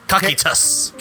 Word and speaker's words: Kokytus: So this is called, I think it's called, how to pronounce it, is Kokitas. – Kokitas. – K-O-K-Y-T-U-S. And it is a rye whiskey barrel. – T-U-S.